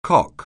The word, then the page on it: cock: anglais